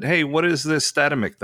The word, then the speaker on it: Statamic: The original intention was Statamic, but I think most people say Statamic because that's what it looks like.